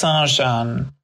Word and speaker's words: Sergeant: One was called Monsieur, which they pronounced Mushu, and the other was called Sergeant, which was pronounced Sarjan.